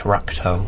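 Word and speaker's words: Ratko: So it's the arrest of, I'm going to say this wrong, I guess, Ralko Mladic. – Oh, yeah, so close, but it's Rakto.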